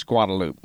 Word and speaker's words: Guadalupe: In Austin, the drag in front of the University of Texas campus is spelled Guadalupe, but known as Guadalupe.